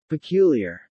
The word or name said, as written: peculiar